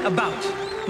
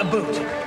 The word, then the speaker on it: about: I speak English and French, not American, and I pronounce it about, not a boot.